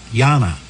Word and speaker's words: Yanni: Yanni, Y-A-N-N-I. Did I pronounce it right? – Well, it's actually Yanni, like the artist, although no relation.